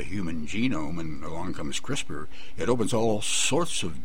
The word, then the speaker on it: CRISPR: Incidentally, for the audience that don't know, it's a capital C, capital R, capital I, capital S, capital P, capital R, pronounced CRISPR, but it all stands for a big, long name, and that we just call it CRISPR.